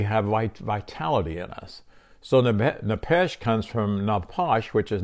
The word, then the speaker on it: nephesh: It's nephesh, N-E-P-H-E-S-H, and it's pronounced nephesh, F-E-S-H, and which it means a breathing creature, and it means vitality.